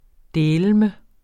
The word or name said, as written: dæleme